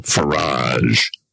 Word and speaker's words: Farage: politician called Nigel Farage. Rhymes with garage or fromage.